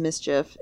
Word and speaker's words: MSCHF: District Court in New York approved Nike's request for a temporary restraining order against MSCHF, pronounced Mischief,